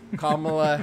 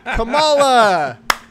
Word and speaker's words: Kamala: Joining me from an equally hot part of California, California native and our new vice presidential candidate, Kamala Harris. Kamala, how you doing? – No, don't say it that way. – Kamala. – Okay. Yeah. Okay. That's the correct way to say it.